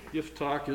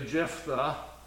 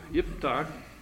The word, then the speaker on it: Yiftach: Yiftach is the Hebrew pronunciation of what we might say Jephthah, but it's really Yiftach.